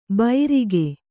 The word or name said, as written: ಬೈರಿಗೆ